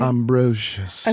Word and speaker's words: Ambrosius: One more time for me. Ambrosius. Is that how you said it? – Ambrosius. – Okay, so some people do say Ambrosius, right? – Okay. – Then it's Ambrosius. I don't even know how to pronounce my own name anymore.